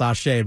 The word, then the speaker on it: Ase: By the way, Ashe spelled A-S-E. By the way, it's not A-S-E wrestling. It's pronounced Ashe, but it's A-S-E is how you spell Ashe.